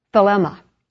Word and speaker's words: thelema: Will is the word thelema. I guess that's the way you pronounce it, T-H-E-L-E-M-A. It is God's deliberate resolve.